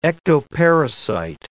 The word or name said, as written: ectoparasite